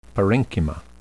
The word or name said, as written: parenchyma